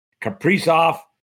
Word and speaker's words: Kaprizov: Kaprizov, is that how you pronounce his name? – Yeah, that works.